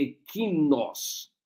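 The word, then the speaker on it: ekinos (ἐκεῖνος): Eu pronuncio ekeinos. A pronúncia melhor seria ekinos. Estou dizendo porque tem pessoas mais não versadas no grego nos escutando. Você escreve ekeinos, pronuncia ekinos, ele.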